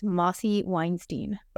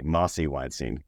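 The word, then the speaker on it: Mosse Weinstein: It's counterintuitive. It's Mossy Weinstein. – Okay, Mossy Weinstein.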